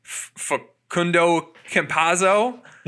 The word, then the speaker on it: Facundo Campazzo: They're playing, I'm going to say his name wrong, Facundo Campazzo.